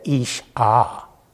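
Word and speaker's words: Ish Ah (אִשָּׁה): he said, Ish Ah. That's how you pronounce it.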